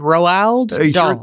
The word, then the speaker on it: Roald Dahl: It's like Ronald without an N. – Right. – It's Roald Dahl.